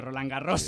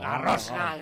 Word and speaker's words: Roland Garros: ¿Habéis visto qué bien pronuncio Roland Garros? – Sí, ¿verdad? Sí. – Perfecto francés, Garros.